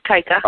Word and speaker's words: Tyka: It's Tyka.